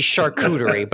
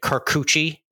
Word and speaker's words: charcuterie: That would be charcuterie, but unfortunately, yeah. the Jets lineman pronounced it Carcucci, and he went super viral for that.